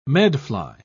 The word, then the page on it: medfly: British English